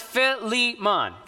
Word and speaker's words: Philemon: No, it is, we believe it's pronounced Philemon. So is the best way to pronounce it.